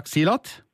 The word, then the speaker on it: silat: The fight scenes feature a, a lesser known Indonesian martial art called, I'm not sure if I'm saying this right, silat. – That's almost perfect. Yeah, well done.